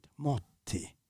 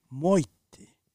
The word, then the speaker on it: morti: So in Catania, for example, if you say the word for morte, death, it's in a regular Sicilian would be morti. In Catania, they would pronounce it motti. The RT becomes double T, motti. Mm. In Palermo, that same word, morti, becomes moiti.